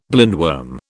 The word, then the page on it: blindworm: British English